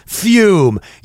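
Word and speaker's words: Füm: Also, we're going to tell you about the good friends of ours from Canada, Fume. You've heard about them. We've been telling you about them. Fume, it's uh, pronounced fume, but it's got two dots over the U, which is very cool.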